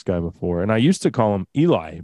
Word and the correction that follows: Elie: because his name is spelled E-L-I-E. But I heard this lady talking to him on this clip, and his name is Ellie, which is a girl's name.